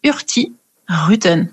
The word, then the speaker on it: Hurtigruten: On ne prononce pas Hurtigruten, mais Hurtigruten, parce qu'en fait, étymologiquement, ça vient de Hurti, qui veut dire rapide, mais on ne prononce pas le petit G, et Ruten, qui veut dire route, donc du coup on prononce Hurti Ruten.